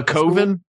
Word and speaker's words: coven: A coven? – Coven? – Yeah. If you're Mark Borchardt. Coven rhymes with oven, so you can't use that.